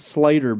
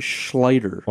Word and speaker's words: Schlater: Not Slater, but Schlater. – Well, you know, I don't know for sure. It's in LaFleur County. It's spelled C-A-C-S-C-H later. – So we'll Schlater. Schlater. – Feels like there should be a sh on there, right? – See you, Schlater.